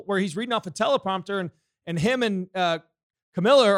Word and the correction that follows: Kamala: are talking back and forth. Or what's her name? – Kamala. – Kamala. I know everybody gets in trouble for pronouncing it wrong, so I want to make sure I pronounce it correctly.